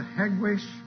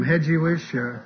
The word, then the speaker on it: Hegwisch: I have to correct Brother Glenn on one thing. That's the Hegwisch Baptist Church. But that's all right. You have to live in Hegwisch to either be able to spell it or pronounce it right. Everybody, they call it everything from Hedgewish to Bethsaida or whatever he called it a while ago.